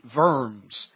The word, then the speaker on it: Worms: The diet of, it's spelled Worms, they would pronounce it Worms.